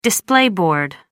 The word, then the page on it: display board: American English